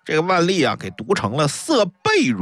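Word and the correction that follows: 勃: 如也”。张居正立刻高声厉喝：“ 应该读薄！”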